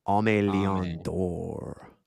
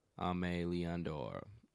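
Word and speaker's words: Aimé Leon Dore: I don't know, I can never say LD Amini Leon Denor. How do you say Ame. – It? – Ame Leon Dor. – Ame Leon Dor.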